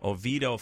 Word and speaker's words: Oviedo: Yeah, and we just saw, is it, how do you pronounce it, Oviedo? – Oviedo, Florida.